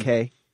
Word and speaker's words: Takei: It's mm-hmm. Takei. Rhymes with gay.